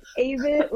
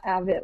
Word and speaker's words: Avett: it's like, it's Avett like crave it, not Avett like have it.